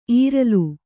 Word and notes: ಈರಿಲು: kannada

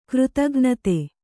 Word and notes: ಕೃತಘ್ನತೆ: Kannada

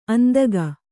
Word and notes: ಅಂದಿಗ: kannada

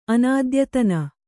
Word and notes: ಅನದ್ಯತನ: Kannada

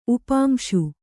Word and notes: ಉಪಾಂಸು: upāmsu; Kannada

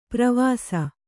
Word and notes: ಪ್ರವಾಸ: pravāsa

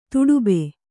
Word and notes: ತೊಡಬೆ: toḍabe

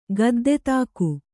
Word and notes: ಗದ್ದೆತಾಕು: Kannada

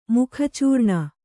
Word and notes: ಮುಖಚೂರ್ಣ: Kannada

